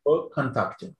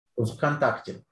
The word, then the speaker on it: Kontakte: Or how do you pronounce it? I'm butchering it. – Kontakte. – Say it again. – Kontakte. It's two words.